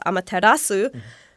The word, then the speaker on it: Amaterasu: in the beginning, and then created the Japanese archipelago, apparently, and then the goddess Amaterasu, the sun god, is considered to be the originator of all gods living in Japan. – You nailed that pronunciation. That was very good.